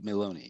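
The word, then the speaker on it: Meloni: It's Giorgia Maloney.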